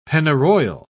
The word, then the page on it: pennyroyal: British English